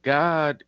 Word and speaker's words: Gad: G-O-D. And at the same time, there's also, people don't know, there's a tribe of Israel called G-A-D, which you would think is Gad, but it's pronounced as God, right?